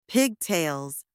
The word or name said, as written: pigtails